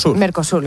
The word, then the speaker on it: Mercosur: Mercosur, lo he dicho bien.